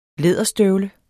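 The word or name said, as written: læderstøvle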